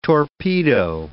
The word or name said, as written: torpedo